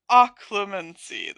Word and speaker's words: Occlumency: I literally just looked it up and it sounded so funny. Occlumency. Oh, crap. Now I have to look it up again. Occlumency. Occlumency. Okay. Occlumency. That's how you're supposed to say it.